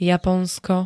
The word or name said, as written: Japonsko